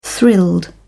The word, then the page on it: thrilled: British English